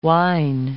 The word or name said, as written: wine